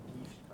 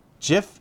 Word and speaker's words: GIF: GIF is spelled the same way as peanut butter. Okay. JIFF is GIF. – It's graphics interchange format. Graphic starts with a G.